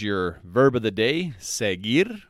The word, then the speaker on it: seguir: Okay, now the verb of the day is seguir, and seguir means to follow. Now, seguir is spelt S E G U I R, seguir.